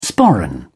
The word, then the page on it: sporran: British English